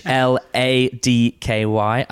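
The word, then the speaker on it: Hladký: Uh, I'm talking about Vaclav Ladky, H L A D K Y. I'm pretty sure the pronunciation is Ladky.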